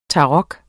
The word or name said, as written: tarot